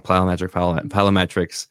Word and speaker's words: plyometrics: You know, you work your legs, squats and all this jumps and plyometrics. I always say that wrong. Plyometrics. – Plyometrics. – Thank you.